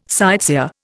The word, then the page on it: sightseer: Indian